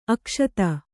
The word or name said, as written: ಅಕ್ಷತೆ